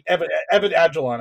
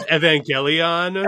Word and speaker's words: Evangelion: Or Evan Agilon. I can't say it right. I fucked it up. – No, Evan Agilon. That's it. – No, I'm totally wrong. – I believe it's Evangelion. – Evangelion. Yeah, that's it.